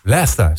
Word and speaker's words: Leicester: Leicester, zo moet je het uitspreken.